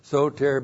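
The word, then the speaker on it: soter (σωτήρ): Soter looks like that in the Greek, S long O T long E R, soter. Soter means Savior, Deliverer.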